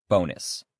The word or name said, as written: bonus